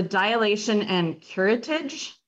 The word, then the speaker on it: dilation and curettage: But it's a dilation and curettage. Is that how you say it? Yeah.